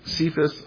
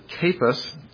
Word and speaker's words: Cephas: But go just with, "You shall be called," it's Cephas, or actually I heard people pronounce it Cephas, at any rate.